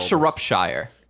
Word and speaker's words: Shropshire: I like Shropshire. – Shropshire.